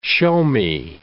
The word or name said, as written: show-me